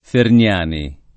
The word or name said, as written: Ferniani